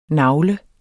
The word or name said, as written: nagle